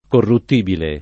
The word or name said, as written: corruttibile